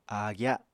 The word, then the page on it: áagya: apatani